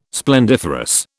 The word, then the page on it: splendiferous: British English